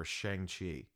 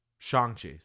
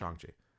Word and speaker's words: Shang-Chi: We got our first trailer, first like full trailer for Shang-Chi. – Yes, and isn't that now you're saying it wrong? – Shang-Chi, sorry. Yeah. Shang-Chi.